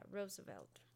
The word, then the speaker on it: Roosevelt: This is by Theodore Roosevelt. Did I say that right? Yeah, Roosevelt.